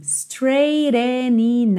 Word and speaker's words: straightening: Straightening now to do. Porque, ó, straightening, o GH é mudo.